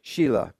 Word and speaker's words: Shelah: It's a long E. Can you imagine that poor guy? Shelah.